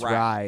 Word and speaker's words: Rai: And Ray, R? – I believe it's Rye. – Rye, ooh, yeah. R-A-I. Shouts out to Rye, 9:30.